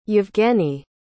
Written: Evgeny

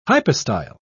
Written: hypostyle

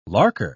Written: larker

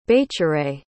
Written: baecere

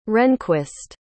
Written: rehnquist

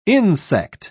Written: insect

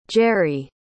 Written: jerri

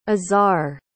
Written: azhar

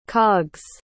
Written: cog's

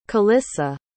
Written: calissa

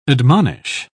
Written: admonish

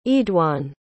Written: eadoin

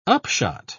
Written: upshot

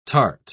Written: tart

